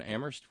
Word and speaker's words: Amherst: And thank you to Terry in Amherst, where only the H is silent.